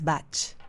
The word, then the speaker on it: Bach: Entonces el apellido es galés y su correcta pronunciación es Bach.